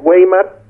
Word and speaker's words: Weimar: Se escribe Weimar con W, eh, eh, Weimar sea, se dice Weimar, es una república impuesta y también eh, costos de guerra, em, muchas cosas, ¿no?